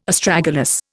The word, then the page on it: Astragalus: Indian